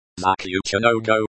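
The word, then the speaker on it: Zakluchinogo (Заключённого): Zakluchinogo, stress on the third syllable.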